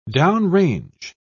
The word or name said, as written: downrange